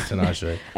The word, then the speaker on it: Tinashe: Tina Shay, she's lit. It's no, Tinashe. It is Tinashe.